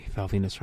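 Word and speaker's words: Val Venis: And by the way, Val Venus rhymes with penis, in case you weren't sure.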